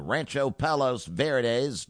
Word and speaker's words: Rancho Palos Verdes: at the Trump National Golf Club in Rancho Palos Verdes.